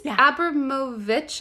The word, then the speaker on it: Abramovic: Is it Abramovic or Abramovic? – So it's Abramovic, I believe.